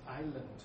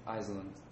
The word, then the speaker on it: Island: Island. – Island. Iceland. – A good one. The S is mute, right? – Oh, okay. – Don't pronounce it.